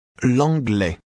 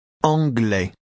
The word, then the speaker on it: l'anglais: L'anglais. L'anglais. L'anglais. – How do you say English? – L'anglais. – Now say the same word, leaving off the L sound at the beginning. – Anglais.